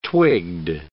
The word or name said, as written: twigged